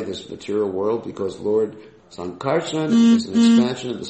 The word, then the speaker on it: Sankarshan: It's not Sankarshan. You know, just teaching devotees the right pronunciation. It's Sankarshan. Got it? So next time you pronounce this name, don't say Sankarshan. That actually would be the Pali pronunciation of the word. But it's Sankarshan.